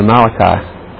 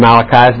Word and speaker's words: Malachi: And somebody said, well, how do you know it's not Malachi? It's not Malachi. It's just an emphasis on the wrong syllable.